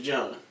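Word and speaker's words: Jean: It was John. – John. – You know, it's like got that, it's John though. It's spelled like Gene, but it's John.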